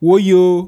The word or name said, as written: Woyo